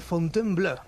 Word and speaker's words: Fontainebleau: Y es que un 27 de octubre, pero de hace 218 años, se firmó en Francia el conocido como Tratado de Fontainebleau.